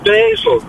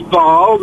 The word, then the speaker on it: Basel: She still said basil. Sort it out. – Right, hang on. – The French is Basel. The German is Basel.